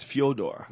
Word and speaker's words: Fyodor: It's actually not even Fedor. Do you know that's what we call it? It's Fyodor. – Yeah. – A Russian friend of mine from Jiu-Jitsu was explaining how you actually say his name. It's Fyodor. Fyodor. Yeah, like Dostoevsky.